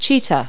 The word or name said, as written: cheetah